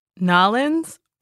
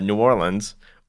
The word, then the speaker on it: New Orleans: Nolens is just a jokey touristy thing. So the only acceptable ways to say it are New Orleans or the most accurate way, which is the extra syllable of New Orleans.